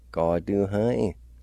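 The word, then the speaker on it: gaduhin: Number four, city, Gaduhai.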